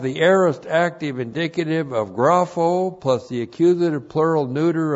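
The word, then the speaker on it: Grapho (γράφω): Grapho, G-R-A-P-H, long O, grapho. And grapho is the Greek word that means to write.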